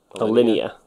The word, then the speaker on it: Alinea: Alinea.